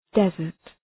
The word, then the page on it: desert: dı’zɜ:rt